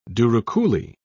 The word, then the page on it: douroucouli: British English